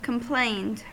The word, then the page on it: complained: American English